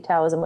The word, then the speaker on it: Taoism: So I didn't go to church anymore, and I've pretty much been into Taoism, even though it's spelled with a T, Taoism, which is actually a philosophy and way of life on do good to yourself.